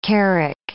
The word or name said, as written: carrack